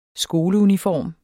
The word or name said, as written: skoleuniform